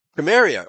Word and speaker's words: Cimmeria: The letter C throughout history before then was pronounced like a letter K, as in Cimmerian, spelled C-I-M-M in English, but still pronounced Cimmeria, or in Greek, Cimmerioi, with a K.